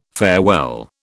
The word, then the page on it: farewell: British English